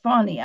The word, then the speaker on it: Fani: One of the most dead, Alvin Bragg is the most dead, and then Fannie Williams. I know they want you to pronounce that Fani. I just can't. That's not how it's spelled.